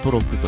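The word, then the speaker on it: 渡鹿: 渡るっていう字と鹿という字で、はい、えー、トロクと読むんで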